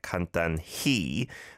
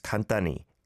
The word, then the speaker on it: 간단히: And yeah, it is a he and then an E vowel sound there, 간단히, but often pronounced just 간단히, 간단히 먹어, like that, not over-pronouncing the h, he sound.